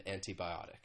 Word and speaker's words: antibiotic: Again, often people would say an antibiotic, and you hear that true T sound.